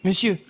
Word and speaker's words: monsieur: Monsieur. – Ah oui, c'est le mot « monsieur ». Dans ce cas-là, « on » se prononce « e », ben oui, un « e » un peu fermé.